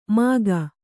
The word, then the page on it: ಮಖ: Kannada